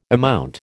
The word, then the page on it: amount: American English